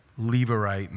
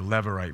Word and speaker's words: Levirate: That's where that Levirate marriage comes in, or Levirate marriage. I've heard it pronounced both ways, so take your pick, I suppose.